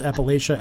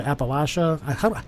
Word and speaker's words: Appalachia: Did I say that right? I sometimes pronounce Appalachia, Appalachia. I'm from Detroit. So, well, as the Appalachia Appalachia divide is you know, it's kind of how you can tell, you know, whether you're from the northern mountain range or the southern mountain range.